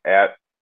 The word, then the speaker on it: Ezio: It's called Beyond Alpha Ezio Orlandi. For Twitter, you can find me at Ezio Orlandi. Ezio is spelled E-Z-I-O, last name Orlandi, O-R-L-A-N-D-I.